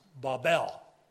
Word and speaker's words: Babel: By the way, it's pronounced Babel in Hebrew. I know some people say Tower of Babel, but it's Babel.